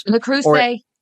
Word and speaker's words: Le Creuset: But like, there's Le Creuset? I can never pronounce it. – Le Creuset. Le Creuset, yeah.